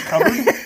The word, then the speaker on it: cupboard: Have you thought about making a cardboard or cardboard? – The word is cupboard. But it is spelled cupboard.